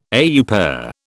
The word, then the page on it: au pair: British English